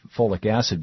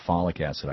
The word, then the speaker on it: folic acid: The FDA started requiring that folate or folic acid be put into, I used to always call it folic acid. I guess you're right. The correct pronunciation would be folic acid.